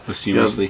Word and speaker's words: posthumously: He will go into the Hall of Fame, no doubt, posthumously. How do you say that? – Posthumously.